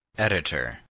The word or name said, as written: editor